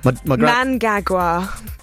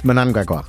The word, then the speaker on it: Mnangagwa: I was saying it wrong for like a day. – Oh, well, what did you say before? – Mnangagwa, because of a pronunciation guide which was sent down, which is incorrect. – Mnangagwa? – Yeah, Mnangagwa. – It's Mnangagwa.